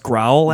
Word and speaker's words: Grohl: His name is pronounced Growl, actually.